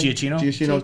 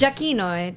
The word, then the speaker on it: Giacchino: The music analysis Giacchino. With Dave Collins. I did that would be great, especially comparing Michael. Say his name again. – Giacchino to the classic John Williams. – Giacchino, Italian.